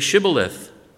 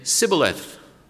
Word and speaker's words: Shibboleth: And if he would say no, they would say to him, "Then say Shibboleth." And he would say Sibboleth, for he could not pronounce it right.